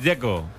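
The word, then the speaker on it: Džeko: De Z, ¿cómo se pronuncia? – Dzeko. Bruno Dzeko. Dice que es Dzeko. – Como tirando a J, Dzeko o algo así.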